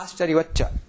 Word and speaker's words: आश्चर्यवच्च: तत्र उच्चारणार्थं सौकर्यार्थम् उच्चारणार्थं तत्र तकारस्य स्थाने चकारः आश्चर्यवच्च तत्र तकारस्य स्थाने चकारः आगतः